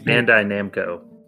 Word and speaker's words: Bandai Namco: Bandico Namco Winter Meltdown Sale. I don't know if I pronounced that right, but I think it's Bandai Namco.